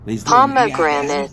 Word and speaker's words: pomegranate: Pomegranate. – Is there an E at the end? – Pomegranate. – Is that silent? – Not. You pronounce the last syllables like N-U-T.